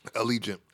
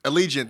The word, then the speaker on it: Allegiant: it's the Allegiant or Allegiant. I don't know how to pronounce it. – Allegiant. – Allegiant. Thank you.